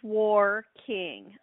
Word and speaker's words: Warr-King: Is it Warking, Warking, how do I pronounce the name of the winery? – It is Warking.